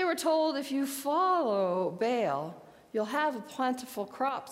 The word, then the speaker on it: Baal: But then there was also God Baal or Baal. Both pronunciations are correct.